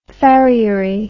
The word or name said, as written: farriery